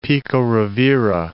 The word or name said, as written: Pico Rivera